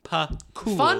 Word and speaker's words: Pakula: I tweeted today, can someone tell me how to pronounce Pakula? – It's Pakula.